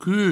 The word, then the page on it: qu: provençal